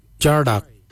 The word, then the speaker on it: Djardag: E-N-D-A-L, jindal. Then we'll look at jardak. Jardak is grey. Jardak. Jardak is spelled D-J-A-R-D-A-G. Jardak, grey. We'll now look at the word for brown. Jittery. Jittery is spelled D-J-E-R-D-A-R-I.